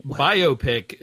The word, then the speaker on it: biopic: Biopic is the correct way to pronounce it. That's what the Oxford Dictionary was going to tell us. – Biopic. Okay, got it. From now on, I'll say biopic.